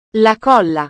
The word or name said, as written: la colla